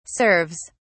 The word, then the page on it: serves: British English